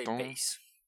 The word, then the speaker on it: Bass: Or I mean, it is spelled Bass, but I tried, like, I looked up pronunciations, and it really depends on your accent, apparently. But as he was originally from Britain, I'm going to say Bass.